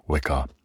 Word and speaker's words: Wicca: However, as neo-paganism is becoming increasingly popular, Yule is celebrated by pagans and in most forms of vicar as an alternative to Christmas. Is it vicar or is it Wicca? It's Wicca, isn't it? You pronounce the W. Wicca.